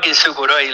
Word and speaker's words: Kintsukuroi: Sí, tranquilo, se llama Kintsukuroi. Lo que pasa que, bueno, al principio es un poco raro, pero realmente se pronuncia tal como se lee, digamos, no hay ninguna pronunciación más, pero sí es verdad que hay algunos fonemas ahí que se juntan raros.